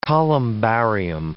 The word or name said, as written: columbarium